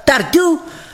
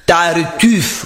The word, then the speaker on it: Tartuffe: Am fost și la Richard și la Tartuf. – Tartuf, nu Tartu. Tartuf.